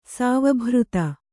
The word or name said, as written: ಸಾವಭೃತ